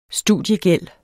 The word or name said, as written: studiegæld